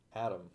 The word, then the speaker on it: Adam: Adam.